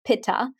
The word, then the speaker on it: Pitta: So for example, Pitta time of day, Pitta, which we pronounce in the West, Pitta.